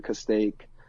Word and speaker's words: Castaic: He lived in a city called Castaic, which is about 70 miles. – How do you spell that? – C-A-S-T-A-I-C, Castaic.